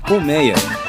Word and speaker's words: colmeia: Colmeia. Colmeia é aberto.